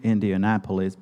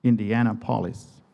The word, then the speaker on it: Indianapolis: The state is Indiana, right? Indiana. And polis is the Greek word for city. So it's Indiana City, like Kansas City. So why wouldn't you pronounce it Indiana City, Indiana polis? It's Indianapolis. It is a misplaced emphasis.